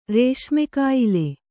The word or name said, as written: ರೇಷ್ಮೆಕಾಯಿಲೆ